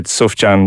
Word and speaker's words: Sufjan: In fairness, I have always said Sufjan because that's how it's written and I've heard it. Other people say it. – Pronounce it, yeah. – I looked it up and he's apparently Sufjan.